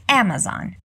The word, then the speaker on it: Amazon: Notou como a pronúncia é diferente de como estamos habituados a falar em português? Ao invés de Amazon, nós dizemos Amazon. Repeat, Amazon.